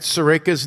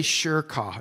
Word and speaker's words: Surekha: If you don't know how to spell it, it's Sure, S-U-R-E. This is how I memorized. And then Ka, K-H-A. That's how I memorized your name. How do you pronounce that? Just say Sure Ka. No, I'm kidding. Surekha.